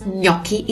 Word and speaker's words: gnocchi: So the correct way to pronounce gnocchi is this. Keep pushing your tongue. Gnocchi.